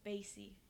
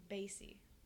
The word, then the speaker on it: Bas: I finally figured out how you say his name, because it's B-A-S. It's Basie. – Oh! – That's how you say it. It's Basie. – Wait, Marky Basie? – No, Basie, B-A-S.